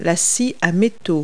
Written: hacksaw

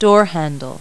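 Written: door handle

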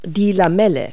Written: gill

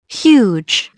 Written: huge

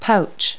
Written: pouch

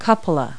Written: cupola

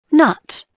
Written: nut

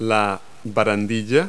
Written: railing

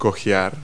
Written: limp

